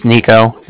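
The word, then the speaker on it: Niko: My name's Nico. That's N-I-K-O.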